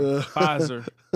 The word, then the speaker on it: Pfizer: If you don't put that P in front of the Pfizer, your ass is going to be done. Niggas going to spell it F-I-Z-A-R. Pfizer.